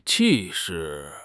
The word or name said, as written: 气势